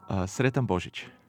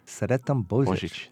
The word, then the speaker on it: Sretan Božić: Sretan Božić. – Sretan Božić.